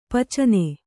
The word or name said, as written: ಪಿಚ್ಚನೆ